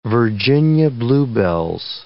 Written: Virginia bluebells

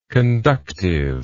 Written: conductively

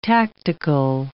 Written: tactical

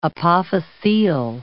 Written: apophyseal

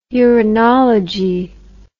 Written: urinology

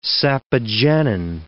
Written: sapogenin

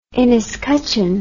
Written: inescutcheon

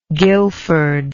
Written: Guilford

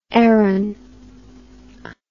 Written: Aran

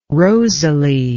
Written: rosalie

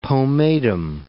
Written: pomatum

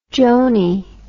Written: joanie

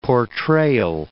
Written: portrayal

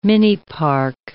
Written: minipark

